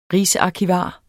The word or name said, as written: rigsarkivar